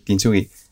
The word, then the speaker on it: kintsugi: y no sé si lo pronuncio bien, kinsugi kintsugi. – Sí. – ¿No? – Sí. – Kintsugi.